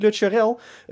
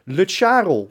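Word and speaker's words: Lutsharel: Het is niet Lutscharel, het is Lutscharel. – Ja, maar moet rijmen op Karel.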